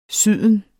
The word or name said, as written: syden